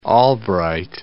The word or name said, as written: Albright